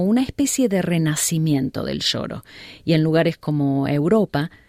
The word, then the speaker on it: choro: El choro, que se escribe con C al comienzo y se pronuncia sh, es un estilo de música brasileña muy antigua que se desarrolló en Río de Janeiro en 1870.